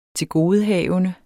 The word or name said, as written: TILGODEHAVENDER